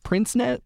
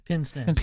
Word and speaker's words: pince-nez: Prince-nez? – Pince-nez.